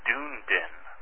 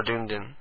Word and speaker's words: Dunedin: Did I say it right? Is it Dunedin or Dunedin? – I think it's Dunedin.